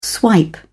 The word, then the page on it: swipe: British English